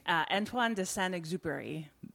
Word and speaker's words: Antoine de Saint-Exupéry: Antoine de Saint-Exupéry.